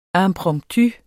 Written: impromptu

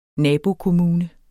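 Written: nabohus

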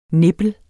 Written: nippel

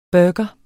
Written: burger

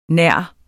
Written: nærmere

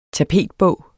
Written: tapetbog

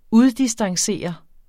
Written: uddistancere

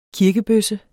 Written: kirkebøsse

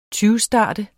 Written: tyvstarte

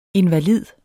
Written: invalid

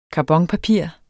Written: karbonpapir

